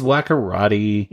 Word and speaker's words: Wakkorotti: It's Wakkorotti.